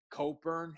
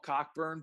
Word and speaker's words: Cockburn: They got the kid Coburn. His last name is spelled Cockburn. So I call him Cockburn, but it's not exactly how it's pronounced.